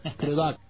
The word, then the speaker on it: Crudup: Crudup. – Not duck. Crudup. – Crudup.